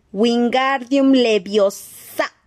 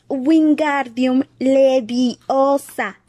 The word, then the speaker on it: Wingardium Leviosa: Wingardium Leviosa. gritó, agitando los largos brazos como un molino. Lo estás diciendo mal. Harry oyó que Hermione lo reñía. Es Wingardium Leviosa. Pronuncia gar más largo y claro.